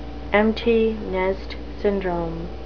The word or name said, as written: empty nest syndrome